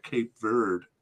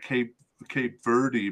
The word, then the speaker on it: Cape Verde: And then they proceeded to the semi-final where they lost to Cape Verde. I've been calling them Cape Verde, but I listened to a pronunciation on the internet today, and it's apparently Cape Verde.